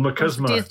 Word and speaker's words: machismo: Or machismo. – Do you pronounce it? – Like macho, so it's machismo.